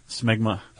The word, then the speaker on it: smegma: Yeah, did you say smegmum? – Smegma. – Okay. – Smegmum's maybe the plural? – No, it's just smegma.